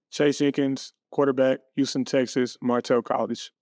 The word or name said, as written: Chase Jenkins